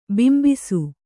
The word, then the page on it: ಬಿಂಬಿಸು: kannada